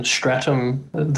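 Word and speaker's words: Statham: So we watched a movie called Parker, and it was a Jason Stratham, did I pronounce it right? – I think so, yeah.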